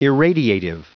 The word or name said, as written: irradiative